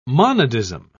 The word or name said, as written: monadism